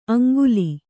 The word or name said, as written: ಆಂಗುಲಿ